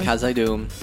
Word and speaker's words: Khazad-dûm: known as Khazad-dûm. – Yes. – If I pronounce that correctly. – Khazad-dûm.